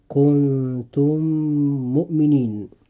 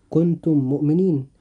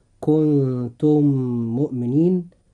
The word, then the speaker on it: كنتم مؤمنين: فننطق هاتين الكلمتين هكذا: كنتم مؤمنين. – ولا ننطقها هكذا، كنتم مؤمنين. فننطقها هكذا: كنتم مؤمنين، بحيث يصير الميمان ميما واحدة مشددة.